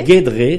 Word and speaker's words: Guédré: Alors, Guédré, c'est comme ça qu'on prononce ? – Guédré, d'accord.